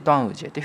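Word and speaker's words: 端午节: ジエというふうに発音します。これをつなぎまして、ドアンウジェというふうに言います。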